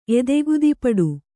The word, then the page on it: ಎದೆಗುದಿಪಡು: Kannada